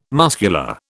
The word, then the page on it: muscular: British English